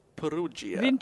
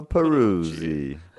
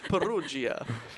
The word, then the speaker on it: Perugia: What had happened was former Louvre worker, an Italian guy called Vincenzo Perugia. Say it with me. – Vincenzo Peruzzi. – Perugia.